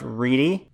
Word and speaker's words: Riedie: Okay, well, my last name is spelled R-I-E-D-I-E. – Okay. – So, which is pronounced Reedy, which of course nobody should ever get right.